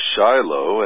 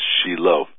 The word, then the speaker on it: Shiloh: you know, I've heard many times people say, Shiloh, and there actually are 65 cities or towns in the United States called Shiloh. So, so I'm really okay with that, even though the actual pronunciation here in Israel is Shiloh.